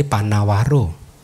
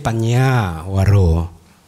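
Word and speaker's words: Paññāvaro: Sehingga kadang juga ada orang membaca Bante Panawaro. Ada ya? Padahal harusnya Bante Panyawaro. Ya, tapi karena tanpa gelombang tadi jadi orang membacanya plainly Panawaro gitu. Padahal enggak, Bante Panyawaro gitu.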